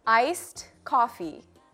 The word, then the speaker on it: iced coffee: Iced coffee. 한국말로는 ice coffee라고 하지만 영어로는 iced coffee. D가 끝에 들어가서 iced coffee라고 발음하는 게 맞아요.